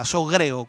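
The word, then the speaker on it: sogreo (ζωγρέω): Ahí el griego quiere enfocarnos en esta palabra sogreo, o sogreo, que vendría a ser con, de esta manera pronunciada, sogreo, con acento en la E, sogreo, alguien prisionero.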